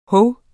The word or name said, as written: hov